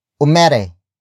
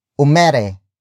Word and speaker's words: umere: For example, umere, umere, umere. Umere is spelled as U-M-E-R-E.